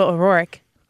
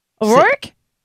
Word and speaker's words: O'Rourke: You add you're adding a syllable. – Where? O'Rourke? – You say...